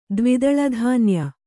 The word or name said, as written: ದ್ವಿದಳಧಾನ್ಯ